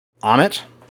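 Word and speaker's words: Ammit: If Moon Knight is the avatar to the moon god Khonshu, Arthur Harrow is the avatar, or the would-be avatar, to the goddess Amit, right? Is that how it's pronounced? – Yes. Yeah.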